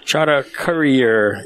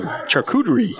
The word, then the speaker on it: charcuterie: Charter courier instead of charcuterie.